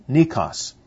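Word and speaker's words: nikos (νῖκος): Victory is the Greek word, would have been pronounced nikos, appears in two manuscripts, as death is swallowed up in conflict, also pronounced nikos. The difference is one is spelled with an I and the other is spelled with an EI, if we were to transliterate that into English.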